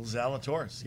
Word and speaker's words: Zalatoris: Will Zalatoris, yes.